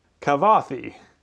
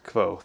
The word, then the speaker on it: Kvothe: This is also another chance for Patrick Rothfuss to kind of dig at all the people who can't pronounce Kvothe. – Kavothi. – Kavothi. – Uh, just Kvothe.